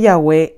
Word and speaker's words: Yahweh: En su libro santifiquemos tu nombre, admiten libremente en las páginas 16 y 18 que Yahweh es la mejor pronunciación del tetragramatón.